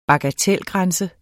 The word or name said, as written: bagatelgrænse